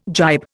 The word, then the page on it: gibe: Indian